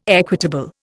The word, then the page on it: equitable: Indian